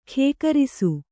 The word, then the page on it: ಖೇಕರಿಸು: kannada